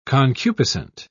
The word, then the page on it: concupiscent: British English